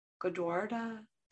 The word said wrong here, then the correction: Gurdwaras: did I say it right? – Gurdwaras. The Gurdwaras. – Yeah. – There you go.